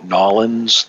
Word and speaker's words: New Orleans: Your two-syllable pronunciation of the city, Nolens, gives away your locale.